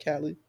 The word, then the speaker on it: Cally: That's how you say it, right, Callie? – I believe so.